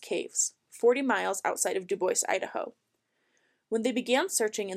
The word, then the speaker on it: Dubois: And before any of you come at me, it is pronounced Dubois, not Dubois.